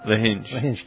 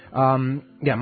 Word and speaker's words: Lahinch: Once we left Marianne on the west coast, Lahinch? Is that how you say it? – Lahinch. – Lahinch.